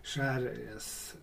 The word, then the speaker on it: Jerez: När man tittar på namnet sherry så är det ju egentligen någon slags språkförbristning bland engelsmännen som uppfattade, när man uttalar Jerez på spanska så blir det mer Jerez, och då blir det sherry på något sätt.